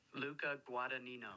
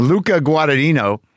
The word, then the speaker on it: Luca Guadagnino: Luca Guadagnino. – I did it. Luca Guadagnino.